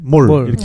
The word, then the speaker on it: 몰: 여기 제주에서는그 짧은 발음을 더 옛날식의 아레아 발음에 가깝게 몰, 몰. 이렇게 네.